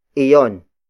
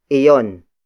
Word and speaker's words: iyon: Then the last pronoun for this category is iyon. Iyon. Iyon. Iyon is spelled as I-Y-O-N.